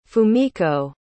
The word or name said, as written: fumiko